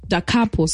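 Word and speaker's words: Da Capo: And yeah, they have upstairs, they have, club SA DJs, Da Copa, Dacapo, sorry, ish, these pronunciations.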